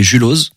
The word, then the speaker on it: Juloze: Eh ben moi sur les réseaux sociaux c'est Julose, donc c'est mon euh, c'est mon nom d'artiste euh, qui s'écrit J U L O Z E.